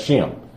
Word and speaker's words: Shem: Now, I know it says S-E-M, but that's Shem. Which was the son of Noah. Again, that's spelled N-O-E and not N-O-A-H, and I'm going to tell you why in a minute.